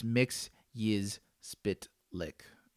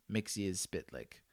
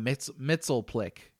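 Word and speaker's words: Mxyzptlk: But there's a scene in the Superman the animated series from the 90s where he gives like a mini tutorial on how to say his name. So it's Mix Yiz Spit Lick. Mix Yiz Spit Lick. If you go back and watch the Super Friends, they butcher that word in the Super Friends. It's like, they call him Mitzelplick in, and I was like, dude, that's not even how it looks.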